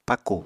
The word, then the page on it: pakù: Apatani